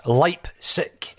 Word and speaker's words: Leipzig: So I have gone as far to consulting YouTube, and YouTube has told me that it's Leipzig. That's the way that you pronounce it.